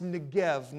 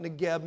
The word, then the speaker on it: Negev: It's pronounced Negev, not Negev.